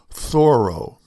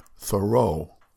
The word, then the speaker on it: Thoreau: For 11 years, our family lived in his hometown of Concord, Massachusetts, where they pronounced his name Thoreau, not Thoreau.